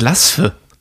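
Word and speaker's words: Bad Laasphe: Wie spricht man Bad Laspe aus? – Ich glaube Laspe, oder? – Laspe, ja, aber was ist ja mit L, also L-A-A und dann S-P-H-E.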